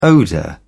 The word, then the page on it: odour: British English